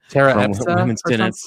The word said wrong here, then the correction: Apisa: Tara Epsa, right? – Tier Apisa. Apisa.